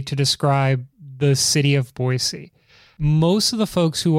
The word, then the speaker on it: Boise: But it's Boise, B-O-I space S-E-E.